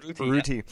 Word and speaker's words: Urruti: How do you pronounce it? Urruti, right? – Urruti. Yeah.